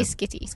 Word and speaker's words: Schiti: Um, I hope that's probably not how you pronounce. – No, I think it might name. be Skitty. Skitty.